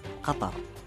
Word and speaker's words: Qatar: The official pronunciation of my country is Qatar.